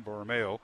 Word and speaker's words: Borromeo: Also, Jude Borromeo. Thank you. I had the accent on the wrong syllable once again. Borromeo.